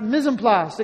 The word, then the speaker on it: mise en place: Pronounced mise en place.